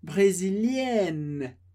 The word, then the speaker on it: brésilienne: Mas quando a gente fala S no final da palavra, assim como E, que vem antes, a gente não pronuncia. Então, a gente termina no som de N, N, brésilienne, brésilienne.